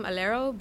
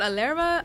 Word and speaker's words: Alero: I was thinking about calling it my name, Alero, but not a lot of people know how to pronounce it. So, yeah. I've been called Alera. I've been called Alerit. I've been called Alaria.